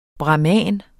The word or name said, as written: bramin